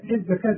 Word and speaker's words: الزكاة: الزكاة تكتب بالواو وتنطق بالألف.